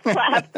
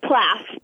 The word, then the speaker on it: PLAAFP: Plaf page. Yes, absolutely. – How did you say that? – Plaf. – Plaf. Okay, fine.